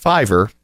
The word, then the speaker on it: Fiverr: I believe it's pronounced Fiverr. It could be Fiverr. Fiverr.